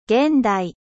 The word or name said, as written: moderne